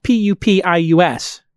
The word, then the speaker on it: Pupius: Well, it's it's spelled P U P I U S, Pupius.